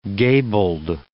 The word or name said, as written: gabled